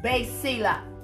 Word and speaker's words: basileia: Listen, that word kingdom in Matthew 6:33 has a Greek word called basila, and that is spelled B-A-S-I-L-E-I-A, basila.